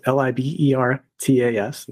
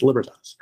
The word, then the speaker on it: Libertas: It's actually pronounced Libertas, but the phonetic spelling is L I B E R T A S. It's Libertas.